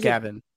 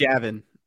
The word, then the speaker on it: Javon: So it's spelled like normal Gavin. – It's not Gavin. – Here's a Mizzou related question. – It's Javon.